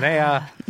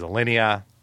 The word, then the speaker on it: Alinea: I said Alinea. It is Alinea.